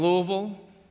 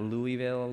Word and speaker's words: Louisville: So I moved from Louisville, and notice I'm saying it right, not Louisville, Louisville. thank, thank you, not bad.